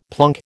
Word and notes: plunk: American English

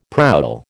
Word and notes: prowl: American English

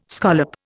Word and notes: scallop: Indian

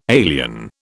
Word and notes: alien: British English

